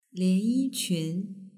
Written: lianyiqun

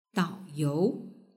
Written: daoyou

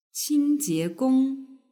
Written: qingjiegong

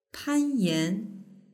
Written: panyan

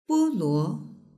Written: boluo

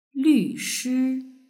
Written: lvshi

